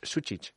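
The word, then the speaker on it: Sučić: ¿Pero cómo es? – Susic. – Es Susic. – Bueno, sé ya. – Sushi. – Susic, ¿no? Yo le escuché. Él hizo su propia. Le preguntaron en su llegada, ¿cómo se dice Lucas Susic? Y dijo, Lucas Susic. – Ah, vale, pues entonces es Susic.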